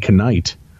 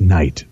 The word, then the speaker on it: Knight: The report said Knight and Michael Johnson had been driving a 2013 white Ford Flex registered to Knight's father, David Knight. The K is silent. You read it your way. I'll read it mine. If there's a K, goddammit, I'm going to read it, Tim.